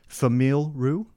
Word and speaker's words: Famille Roux: Famille Roux. F-A-M-I-L-L-E.